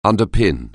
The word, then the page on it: underpin: anglais